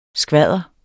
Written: skvadder

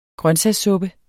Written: grøntsagssuppe

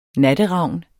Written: natteravn

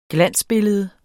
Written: glansbillede

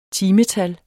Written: timetal

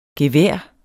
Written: gevær

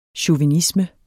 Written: chauvinisme

